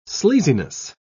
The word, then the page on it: sleaziness: British English